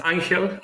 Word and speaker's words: Angel: Yeah, uh, briefly, uh, as you said, my name is Angel. I know that it's quite hard to pronounce it because it's a Spanish name, but actually, I am from Chile, from South America.